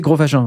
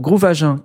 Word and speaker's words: Groove Agent: À ne pas prononcer Gros Agent, parce que ça, c'est pas du tout bon, tu vois.